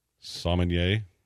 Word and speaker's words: sommelier: Sommelier is how that's pronounced.